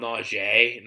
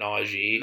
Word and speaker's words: Najee: First of all, they call him Najee, not Najee.